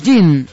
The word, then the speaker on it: Jin: bajo el nombre de Jin, J-I-N, Jin, pronunciado así en japonés.